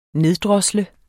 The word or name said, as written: neddrosle